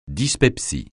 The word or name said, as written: dyspepsie